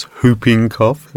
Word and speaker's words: whooping cough: The W is silent, so it's whooping cough, everybody.